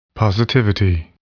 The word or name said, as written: positivity